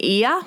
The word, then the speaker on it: Oia: After that, we went to Santorini in Ia, which is spelled O-I-A.